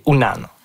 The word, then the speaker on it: Unan: Puis le vrai mot Innu, c'est Unan, ou parfois prononcé Wanan.